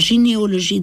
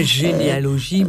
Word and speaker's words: jineoloji: Alors, gynéologie, dans le livre, ça s'écrit J-I-N-E-O-L-O-J-I. Voilà. Alors, effectivement, au niveau du son, on a une racine. – C'est pas généalogie, mais J-I-N-E-O-L-O-J-I.